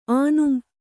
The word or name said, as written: ಆನುಂ